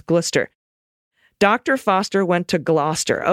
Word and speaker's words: Gloucester: All right, Gloucester. Gloucester. Dr. Foster Gloucester.